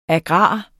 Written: agrar

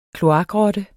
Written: kloakrotte

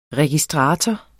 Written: registrator

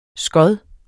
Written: skod

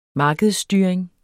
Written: markedsstyring

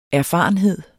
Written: erfarenhed

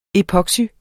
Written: epoxy

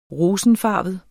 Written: rosenfarvet

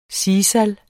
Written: sisal

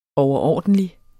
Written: overordentlig

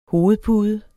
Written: hovedpude

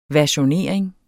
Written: versionering

